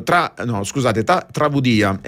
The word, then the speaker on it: Travudia: che è un brano Travudia, questa è la pronuncia più esatta,